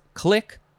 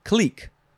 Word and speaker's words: clique: Next is, can be pronounced a couple of ways. It is click or clique. C-L-I-Q-U-E.